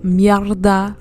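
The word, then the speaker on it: merde: Yesterday at work, I had a mierda day at work, okay? Mierd is how you say it in French. Mierda is how you say it in Spanish.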